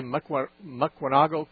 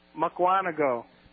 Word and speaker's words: Mukwonago: Did I say Mukwonago correctly? – No. – Not quite. Say it for me then. – Mukwonago. – Mukwonago. That's right. Like I want to go.